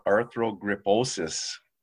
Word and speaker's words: arthrogryposis: we're going to be talking about arthrogryposis, if I'm saying that right.